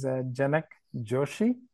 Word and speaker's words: Janak Joshi: My name is Janak Joshi, and I am originally from India.